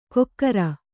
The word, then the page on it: ಕೊಕ್ಕರ: kokkara